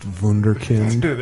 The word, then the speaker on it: Wunderkind: I'm child wonderkind Dan Harmon. – I believe it's pronounced Wunderkind.